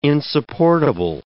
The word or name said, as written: insupportable